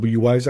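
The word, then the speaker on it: Wwise: And then we take the sounds into a software that's called Wise, or WISE actually. Usually pronounce it Wise for short, but the full name is WISE.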